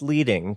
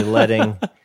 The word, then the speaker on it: leading: But just for anybody listening, like, oh, if you're like, oh, it's pronounced leading, because Brian said it's pronounced leading. Like, nope, it's actually letting.